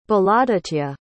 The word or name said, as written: balaaditya